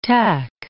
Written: tach

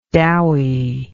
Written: dowiness

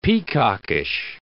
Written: peacockish

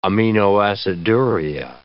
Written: aminoaciduria

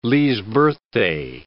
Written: Lee's Birthday

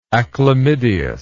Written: achlamydeous

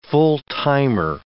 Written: full-timer

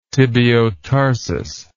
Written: tibiotarsus

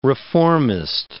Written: reformist

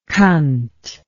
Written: cunt

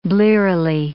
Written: blearily

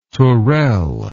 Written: tourelle

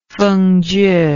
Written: fengjie

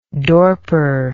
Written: dorper

